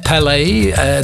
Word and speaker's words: Palais: En het klopt, de titel van het liedje is White Man in Hammersmith Palais. Palace, maar dan op zijn Frans. Palais, dan weer op zijn Engels uitgesproken.